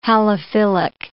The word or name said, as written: halophilic